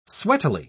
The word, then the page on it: sweatily: British English